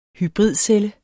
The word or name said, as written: hybridcelle